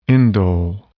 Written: indole

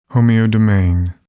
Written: homeodomain

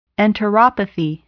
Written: enteropathy

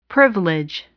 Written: privilege

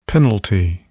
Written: penalty

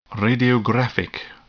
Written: radiographic

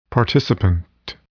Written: participant

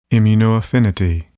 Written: immunoaffinity

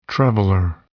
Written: traveler

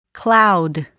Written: cloud

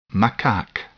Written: macaque

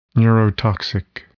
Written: neurotoxic

